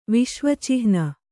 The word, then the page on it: ವಿಶ್ವಚಿಹ್ನ: Kannada